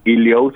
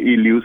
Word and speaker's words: Iliou: Iliou se escribe, Iliou se pronuncia. – Iliou. – Sí, Iliou.